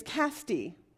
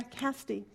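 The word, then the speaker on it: Casty (כשדי): K-A-S-D-I-Y. Cast die? Cast D? D-I-Y? And then there's another one. K-A-S-D-I-Y-M-A-H. But the pronunciation is Casty. Isn't that interesting? Yeah, Casty. That's exactly what it says.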